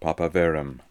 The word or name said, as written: papaverum